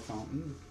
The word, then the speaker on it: Fountain: It's Samuel Fountain. My last name is spelled F-O-U-N-T-A-I-N.